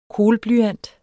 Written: kohlblyant

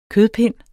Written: kødpind

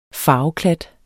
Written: farveklat

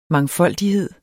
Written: mangfoldighed

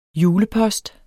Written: julepost

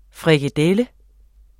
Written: frikadelle

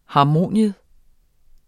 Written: HARMONIUMS

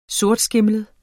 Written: sortskimlet

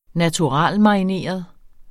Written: naturalmarineret

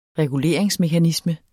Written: reguleringsmekanisme

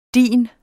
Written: dit

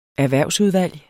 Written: erhvervsudvalg

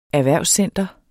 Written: erhvervscenter

